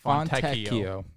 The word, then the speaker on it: Fontecchio: By the way, John is telling us it's pronounced Fontecchio.